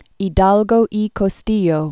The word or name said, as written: Hidalgo y Costilla